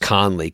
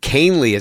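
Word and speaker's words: Kahnle: At which point they bring in Canely, who I thought would be pronounced Conley because it's K-A-H-N-L-E. But Canely is how you say it.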